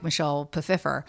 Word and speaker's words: Michelle Pfeiffer: I mean, right now I'm staring at the iconic Michelle Pfeiffer.